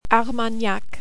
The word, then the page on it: Armagnac: French